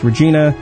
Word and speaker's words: Regina: Sadly, it's not pronounced Regina. Sounds like the ladies' bits, yada, yada, yada.